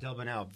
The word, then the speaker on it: Delbonnel: Delbonnel, very nice guy, and he's responsible for a lot of that imagery because he's so skilled with the camera.